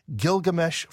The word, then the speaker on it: Gilgamesh: And as proof, he cites the, am I saying this right, Gilgamesh flood legend. Charlie, help us understand what that is and what the argument seems to be. – Yeah, and I think there's actually a simple answer for this man. By the way, you pronounced that correctly.